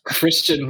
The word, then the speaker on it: Christine: No, sorry, it's Christian Michael. – Christine. Oh my God! spelled like Christine, pronounced Christian.